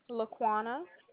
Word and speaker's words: Laquana: Laquana. – Oh, how do you spell that? – L-A-Q-U-A-N-A.